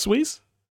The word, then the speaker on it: Suez: Suez. It's connecting the Mediterranean Sea to the Red Sea. – Red Sea. The Black Sea. It's pronounced Suez.